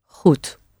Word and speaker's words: goed: Nice long O sound. And the D at the end is indeed like a T, isn't it? Goed. The word for very is heel. H-E-E-L.